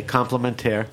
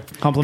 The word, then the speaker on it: Complémen'terre: It's spelled like compliment, but with E R R E at the end. Okay, Complémen'terre. – Complémen'terre.